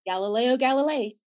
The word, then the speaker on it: Galileo Galilei: Galileo Galilei.